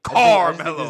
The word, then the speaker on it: Carmelo: The hard C. Carmelo.